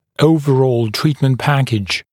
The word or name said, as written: overall treatment package